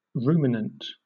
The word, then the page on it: ruminant: British English